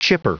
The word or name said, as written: chipper